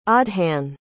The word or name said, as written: aodhan